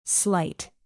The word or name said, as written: slight